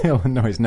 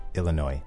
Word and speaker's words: Illinois: Illinois, no. No, there's no S in the pronunciation, just Illinois.